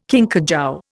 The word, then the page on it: kinkajou: Indian